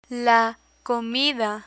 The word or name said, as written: comida